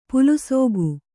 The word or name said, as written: ಪಲಸಿಗೆ